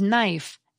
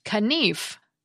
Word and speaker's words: knife: One cluster that's disappeared is KN, which gives us the silent K in words such as knife, knee, and knowledge. Knife, for example, used to be pronounced kanif.